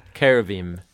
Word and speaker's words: cherubim: How do you pronounce it? – Cherubim. – Cherubim. – Cherubim. – Cherubim. Yeah.